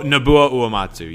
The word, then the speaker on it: Uematsu: And don't know how to say his last name, but Uematsu? – Oh, Nobuo Uematsu, yep.